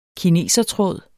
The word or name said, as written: kinesertråd